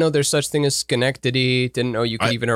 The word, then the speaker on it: Schenectady: So actually Willow, she asked to plug the MOPCO, MOPCO Improv Theater in Schenectady, New York.